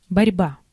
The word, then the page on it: conflict: American English